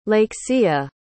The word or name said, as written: Lakeshia